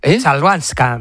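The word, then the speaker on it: Chwalińska: Joder, ¿cómo se dice? Es complicada, ¿eh? Chabalinska. – Chalvanska. – Maja Chalvanska.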